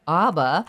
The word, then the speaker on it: ABBA: or ABBA, but I say the music of ABBA. – You say ABBA, and I say ABBA. – Big controversy about that particular pronunciation, but nonetheless, that show will be on Saturday, January 16th, 8 o'clock show, so it's definitely a January date night show.